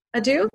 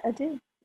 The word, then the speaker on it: adieu: Is that how you pronounce it? Adieu? – Yeah, adieu. I think you got it.